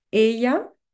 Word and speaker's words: ella: On le prononce ye, ella, virgulilla, qui veut dire tilde en espagnol.